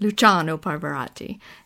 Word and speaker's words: Luciano Pavarotti: Is that how you say it? – Luciano Pavarotti. And a smaller doc called Echo in the Canyon.